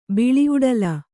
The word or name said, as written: ಬಿಳಿಔಡಲ